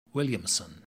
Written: williamson

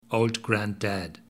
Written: old grand dad

